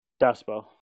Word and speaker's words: Duspo: Yes, yeah, like the Spo, Duspo. If you want to say it pronounced, it would be D-U-H, Spo.